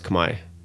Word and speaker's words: Khmer: Cambodian language is pronounced Khmer. K-H-M-E-R. It's not. You don't speak Cambodian. You speak Khmer.